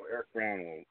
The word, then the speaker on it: Eric Brown: The John is silent, so Eric Brown will suffice.